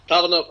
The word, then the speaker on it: Tavernier: Uh, uh, Tavern, uh, sorry. – Well done. Let me pronounce that correctly. Tavernier and,